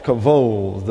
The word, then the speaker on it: kavod: It's pronounced kavod. The B gets a V sound as in Victor, and the D gets a TH diphthong sound in the English, kavod.